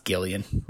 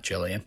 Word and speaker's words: Gillian: which I also learned is pronounced Gillian and not Jillian.